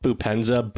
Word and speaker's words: Boupendza: I mean, he called Boupendza. Throughout the game. I don't think he pronounced the P until the very end.